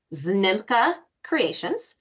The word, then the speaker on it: Znimka Creations: So I pronounce it Znimka Creations.